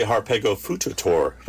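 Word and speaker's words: Harpagofututor: Now, in doing research on this episode, I came across a Harpego fututor? – Harpego fututor. – Is that right? Did I say it right? How do you say it? – I say Harpego fututor.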